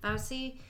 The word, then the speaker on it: Fauci: That's how you say his name? Fauci? – Fauci.